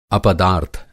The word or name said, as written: अपदार्थ